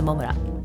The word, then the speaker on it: 百村: J6 さん、あ、我が町稲城、北稲城、好きな難読地名、稲城市のモムラです。モムラ、どうやって皆さん書くか想像つきます。モムラ、稲城市のモムラ、百の村と書いてモムラなんですよ。